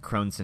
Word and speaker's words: Crohn's: That was for the Crohn's and Colitis Foundation, or am I saying that right? – Yeah, exactly. Yep, yes. – You got it.